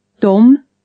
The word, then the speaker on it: dem: Spelt O-C-H. – Dom. – Them, spelt D-E-M. – Or. – To, spelt A-T-T. – La. – Laid, spelt L-A-D-E.